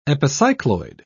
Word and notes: epicycloid: British English

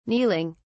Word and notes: kneeling: British English

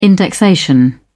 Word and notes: indexation: British English

